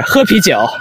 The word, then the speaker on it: 喝啤酒: Just say drink beer. 喝啤酒. – 喝啤酒. – Whoa, your pronunciation is perfect.